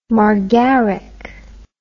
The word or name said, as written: margaric